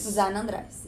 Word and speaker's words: Zanandrais: Zanandrais, yeah.